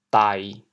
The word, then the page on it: 呆: dai1; 潮阳